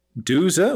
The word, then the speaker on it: douze: Doze, which is spelled D-O-U-Z-E, and don'ts.